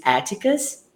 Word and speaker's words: Atticus: The right pronunciation is Atticus.